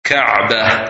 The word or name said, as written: Kabah